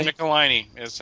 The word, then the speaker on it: Michelinie: Yeah, the 90s with Eric Larson on the book with David Michelinie. Or how do I pronounce it? Michelinie? – Michelinie is how I pronounce it.